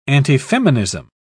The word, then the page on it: antifeminism: British English